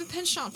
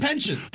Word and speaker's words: penchant: I have a penchant. – A penchant. Oh. Have I been saying it wrong all these years? – We must. – I always said pension.